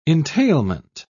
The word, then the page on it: entailment: British English